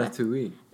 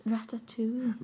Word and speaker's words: Ratatouille: Ratatouille. – Ratatouille.